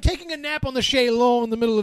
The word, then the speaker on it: chaise longue: You can say lounge. – It's pronounced chaise longue.